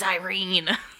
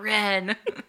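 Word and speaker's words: Yrene: It's Irene. It's not Ren.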